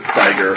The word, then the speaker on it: Sieger: S-I-B-E-R? – S-I-E-G-E-R. – Oh, Seeger. – He pronounces it Cyger, and he doesn't spell it for you, so...